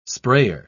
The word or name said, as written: sprayer